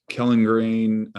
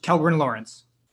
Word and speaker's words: Kellgren-Lawrence: So I'll tell you that, Kellengrain, so grade one arthritis, how do you say that? How do you say that? I forget that. – Kellgren-Lawrence.